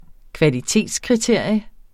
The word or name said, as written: kvalitetskriterium